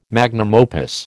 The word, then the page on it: magnum opus: American English